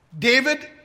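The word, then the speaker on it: David (דוד): David is David in Hebrew.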